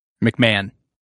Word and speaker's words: McMahon: McMahon. – Oh, that's man. – Typically how it's pronounced.